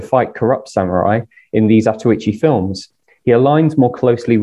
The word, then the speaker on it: Zatoichi: His name literally means blind one, Zato no Ichi, as it is often pronounced in the films.